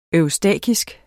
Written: eustakisk

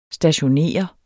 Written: stationere

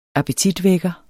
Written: appetitvækker

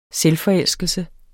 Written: selvforelskelse

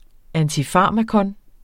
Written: antifarmakon

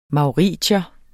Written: mauritier

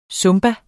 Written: zumba

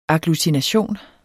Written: agglutination

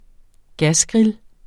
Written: gasgrill

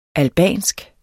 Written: albansk

